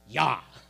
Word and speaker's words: Jah: And if we really wanted to pronounce it right, we would say Yah.